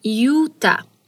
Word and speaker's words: Utah: Aí fica a pergunta, mas Utah começa com U, porém na pronúncia temos um i logo no início, ficando assim, Utah.